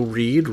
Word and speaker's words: Reid: File, F-E-I-L. And also Letterboxd under the same name. – I am on Twitter at Joe Reed, Reed spelled R-E-I-D.